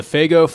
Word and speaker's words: Faygo: Okay, so the Faygo Fireworks is a winner.